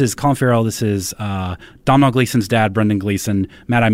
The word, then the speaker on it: Domhnall: It's Domhnall. You spell it like an idiot.